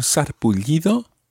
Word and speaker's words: sarpullido: Se siente un poco mareada y, and there's something else wrong with Cara, tiene, she has, un sarpullido. That's one of those words that it's very difficult to guess what it means. Un sarpullido, sarpullido. It's got a double L sound at the end there.